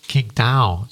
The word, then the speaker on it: Qingdao: Also die ist in jetzt pass auf, ich versuche es auszusprechen, Qingdao. – Qingdao. So ja, spricht man so, das aus.